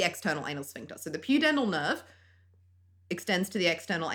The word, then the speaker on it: pudendal: Pudendal nerve. That sounds right. I think pudendal. that's right.